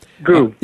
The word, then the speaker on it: Gu: Yeah, and Eileen, is it Gu? Is it pronounced Gu? – Gu.